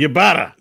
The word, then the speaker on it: Ybarra: Former Xbox executive Mike Ybarra, or is it just Barra and the Y is silent? I don't know how you actually say it. – It's like Yirel. Ybarra. – It's like Yirel. Yirel. It's Ybarra.